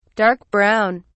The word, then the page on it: dark-brown: British English